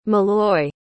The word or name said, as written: molloy